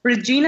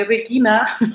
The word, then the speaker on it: Regina: Hi, I'm Regina, as the Americans say. In Germany, we say Regina.